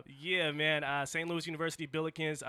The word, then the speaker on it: Billikens: Billikens, did I pronounce that right? – Yeah, Billikens? – Got it.